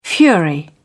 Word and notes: fury: British English

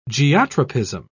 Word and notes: geotropism: British English

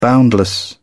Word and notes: boundless: British English

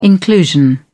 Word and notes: inclusion: American English

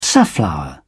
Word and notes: safflower: British English

